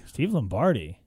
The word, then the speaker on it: Steve Lombardi: Steve Lombardi? – Steve Lombardi.